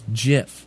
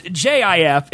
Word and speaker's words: GIF: It's pronounced JIF. It's spelled GIF. That is how the man who created GIF wanted it to be said.